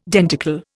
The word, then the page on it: denticle: Indian